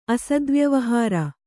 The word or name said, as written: ಅಸದ್ವ್ಯವಹಾರ